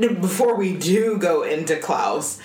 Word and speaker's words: Klaus: It's Claus, I suppose, supposed to be pronounced Klaus, which is how they pronounce it in the movie.